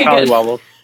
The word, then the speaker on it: collywobbles: Are we saying that right? Did we mess it up? – Yeah. What is it? – Yeah. No, you got it. You got it. – Okay, collywobbles.